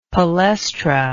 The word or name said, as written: palestra